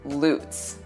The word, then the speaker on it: Lutz: We will start with one that just about everybody mispronounces, the city of Lutz. It's spelled just like the ice skating jump, Lutz, but here it's pronounced Lutz.